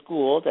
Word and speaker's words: Gould: My name is Adrian Gould, and I'll spell that for you. A-D-R-I-E-N-N-E is my first name. My last name is Gould. That's like gold with a U in the middle of it. Which is, I like to say that. So it's G-O-U-L-D.